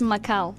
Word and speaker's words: Macau: No, it's Macau. – Oh, yeah. – 澳门 is not pronounced 澳门, it's Macau. And also we have to look out for spelling. – Yes, so the spelling of Macau is M-A-C-A-U. – 对 M-A-C-A-U 而不是 M-A-C-A-O Macau. – Macau, yeah. So some famous foods from Macau include the Portuguese egg tart.